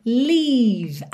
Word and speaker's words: leave: Atenção para você fazer a pronúncia desse leave aqui, que é o L-E-A-V-E, diferente do live, morar ou viver, L-I-V-E. Tá? Então, live é curtinho, é mais vertical, live. Aqui é o leave. Então, saboreia esse som, lembra de deixar ele compridinho, tá?